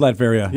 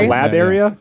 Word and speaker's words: Latveria: Latveria. Yeah, good call. Oh, it actually is Latveria. – Yeah, not Lavaria.